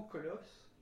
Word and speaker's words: Kolos: Moi, je l'ai prononcé Colosse pendant très longtemps jusqu'à ce que je percute sur le fait que c'était le mot Colosse, écrit K-O-L-O-S.